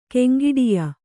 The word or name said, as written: ಕೆಂಗಿಡಿಯ